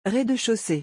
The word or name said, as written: rez de chaussee